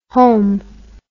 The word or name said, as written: holme